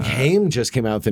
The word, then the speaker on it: Haim: It's Haim.